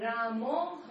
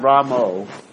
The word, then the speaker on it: Rama: So usually the way it should be pronounced is the Ram, the last A in Rama is a very soft A and short.